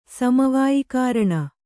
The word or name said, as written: ಸಮವಾಯಿಕಾರಣ